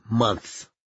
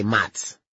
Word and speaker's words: months: For months, that's a tough word for me, months. Because it should be pronounced months. Months. I say for months. Like, I almost say mots. For mots!